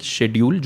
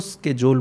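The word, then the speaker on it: schedule: तो आजकल स्केड्यूल ही चल रहा है बट अगर आप ऑक्सफोर्ड यूनिवर्सिटी प्रेस डिक्शनरी को कंसल्ट करेंगे तो उसमें दो प्रोनाउंसिएशन है एक शेड्यूल जो हम बचपन से बोलते आए हैं राइट वो ब्रिटिश इंग्लिश है और अमेरिकन में इसको स्केजोल बोलते हैं आप कोई भी मूवी देखेंगे जिसमें कोई हाँ।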